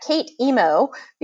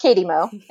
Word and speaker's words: K8EM0: It's K8EM0, and a lot of people pronounce that Kate Emo because it is a personal Twitter account, and I get real on that personal Twitter account, but it's pronounced Katie Mo anyway.